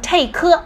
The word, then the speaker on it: take: 首先呢，我们来先看一下 take 的一个发音，字母 t 在这边发的是 t，然后字母 a 呢发的是本身字母的发音就是 a，所以两个连接在一起是 take， OK，所以它正确发音是 take，切记哦，不要把这个 k 念成科，这种感觉就是不要念成 take 啊，是 take。